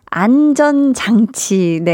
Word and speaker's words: 안전장치: 황수경님은 볼륨 한디가 곧 안정장치. 아, 나 이 발음 진짜 안 되네, 오늘. 네, 아니, 제가 오늘 오프닝 때도 이 발음이 조금 쉽지가 않았는데, 안전장치.